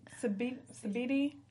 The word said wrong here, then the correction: Sidibe: Oh, Gabrielle Sidibe.